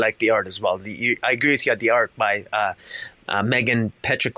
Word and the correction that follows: Hetrick: was, I said that wrong. The art by Megan Hetrick.